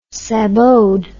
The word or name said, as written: saboted